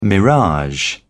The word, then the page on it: mirage: British English